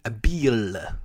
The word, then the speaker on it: Beal: So I need to pronounce it like a like an eel in the ocean. – That's Bradley, how it's pronounced. – Bradley Beal.